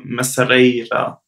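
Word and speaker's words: Mercearia: Äh, ist auch gut, aber ich würde immer zum Lunch zur Messeria, so spricht man es aus, äh, gehen.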